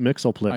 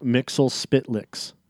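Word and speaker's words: Mxyzptlk: No, it's not Mixelplix. – I can't it's fucking pronounce it's it. It's Mixelspitlix.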